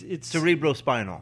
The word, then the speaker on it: cerebrospinal: It's, how do you pronounce it? Cerebinal fluid? It's cerebrospinal.